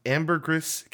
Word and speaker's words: Ambergris: Um, he buys a villa on Ambergris Caye, Ambergris Caye in Belize. I had to check to see if it's pronounced ambergris because that doesn't sound right. It is.